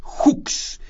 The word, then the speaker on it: Hux: Entonces, en una de estas perforaciones, el pozo se llama Hux, y se escribe H-U-X, en Hux, se detectaron posibilidades de una gran cantidad de oportunidades.